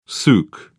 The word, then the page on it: souk: British English